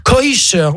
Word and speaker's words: kosher: En fait, c'est juste que les Américains prononcent à la sauce yiddish. – Ah, ouais. – Ils disent koisher au lieu de kasher, ah, oui.